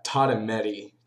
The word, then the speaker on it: Tatumedi: It's Tatumedi, just the way it sounds.